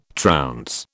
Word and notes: trounce: British English

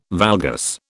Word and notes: valgus: British English

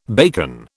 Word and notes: bacon: British English

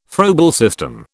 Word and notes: Froebel system: British English

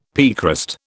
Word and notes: piecrust: British English

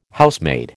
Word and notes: Housemaid: American English

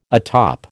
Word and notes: atop: American English

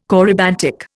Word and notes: corybantic: Indian

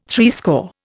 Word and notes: threescore: Indian

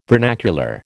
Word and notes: vernacular: American English

